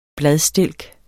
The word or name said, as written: bladstilk